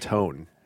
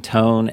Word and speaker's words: Antoon: But it is Tone, and Tone is how you pronounce my last name.